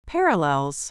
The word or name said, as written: parallels